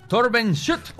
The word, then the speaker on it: Torben Schutt: Alemán, pronunciado en alemán Torben Schutt.